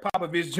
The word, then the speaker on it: Popovich: Papa, I did not say his name right. Popovich Jr. I'm about to bleep that.